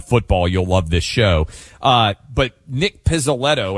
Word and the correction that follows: Pizzolatto: and I may be mispronouncing. – Pizzolatto, yeah.